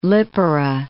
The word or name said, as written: Lipara